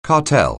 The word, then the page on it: cartel: anglais